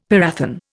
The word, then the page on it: parathion: Indian